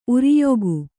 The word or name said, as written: ಊರುಯುಗ